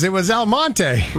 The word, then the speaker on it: Almonte: I figured out what Elmont was. Exclamation point, exclamation point. – Elmonte? – It was Elmonte. – Really? – Huh. Yeah. So it's spelled Almonte.